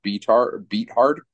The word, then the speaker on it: Beathard: somebody's telling me that it's actually pronounced Beathard, which is just funny.